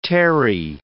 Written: terry

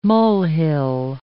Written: molehill

